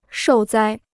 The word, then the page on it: 受灾: shòu zāi